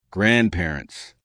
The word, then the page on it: grandparents: American English